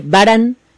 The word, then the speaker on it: Varan: El monstruo de hoy se dice Baran, pero se escribe Baran con B de burro.